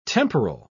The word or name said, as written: temporal